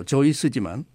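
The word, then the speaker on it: Seuss: 본래 독일어 발음으로 조이스지만 많은 사람들이 수스라고 부르자 그대로 굳어진 것입니다.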